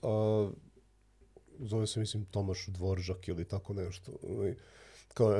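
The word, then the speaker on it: Dvořák: se i Dvoržak piše Dvorak, tako mm da mhm. ja to tako izgovaram, da, da, pa prosto, da.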